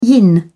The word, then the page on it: yin: British English